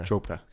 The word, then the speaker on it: Chopra: How do you say that again? Deepak. Chopra, yeah. – Chopra. – C-H-O-P-R-A.